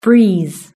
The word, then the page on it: breeze: British English